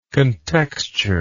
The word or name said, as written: contexture